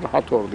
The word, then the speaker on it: Hathor: Hathor diye yazılır, Hathor diye okunur.